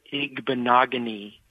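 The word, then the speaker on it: Igbinoghene: Noah Igbinogheni rhymes with mahogany. – Beautiful. – That's a good way to remember it.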